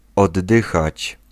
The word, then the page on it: expirer: France; [ɛk.spi.ʁe]